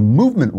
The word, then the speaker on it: MVMT: It's spelled MVMT, so you don't have to pay for all those expensive vowels.